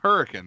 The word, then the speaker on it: Hurricane: Is that how you pronounce it? – It's yeah, Hurricane. That's the name. Hurricane. – Now, yeah. if you go in and call it hurricane, they will bitch and correct you.